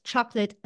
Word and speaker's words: chocolat: So the two words, chocolate and chocolat, sound similar, except in French, okay, we have a silent T. It is spelt with a T in the end, but it's not pronounced.